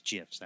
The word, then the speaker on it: GIFs: It's GIFs, actually. I should always pronounce that properly.